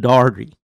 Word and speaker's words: Daugherty: My guest today is Jason Daugherty.